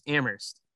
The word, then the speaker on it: Amherst: a lot of people say UMass Amherst, but you don't put the H in it, it's just Amherst.